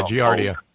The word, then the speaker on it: Giardia: You know, when you started talking about Giardia, is that how you pronounce it? I know what it is. – Yeah, Giardia.